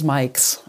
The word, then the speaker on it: MIIICX: Und jetzt muss ich dich mal fragen, M Triple I CX oder wie spricht man es aus? Auch das ist eine Möglichkeit. Ähm, wir, wir, wir, wir, selbst ähm, nennen uns Mikes.